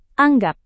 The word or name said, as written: anggap